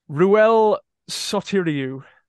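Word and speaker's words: Ruel Sotiriou: Ruel Sotiriu.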